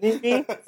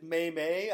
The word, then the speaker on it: meme: Meme? – Meme is the right word. Some people have pronounced it May-May. I believe May-May. Bryce Harper is one of those, but it is meme.